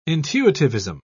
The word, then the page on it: intuitivism: British English